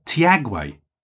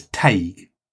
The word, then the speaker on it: Teague: Now, interestingly, I believe it's an Irish name, and the Irish, I think you'll find, pronounce it as Teague. Teague. So I think that is a correct. So Teague is probably incorrect. Teague is the Irish, which I think is correct.